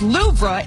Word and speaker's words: Louvre: The weight loss drug is high on the list, along with Storm Eowyn and the Louvre Museum, which is pronounced Louvre in France.